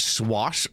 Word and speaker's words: swash: Is it swash or swash? – More like sloshed buckler. – Swash. You gotta put the emphasis on the W, because that's where you get the panache.